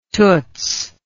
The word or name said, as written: toots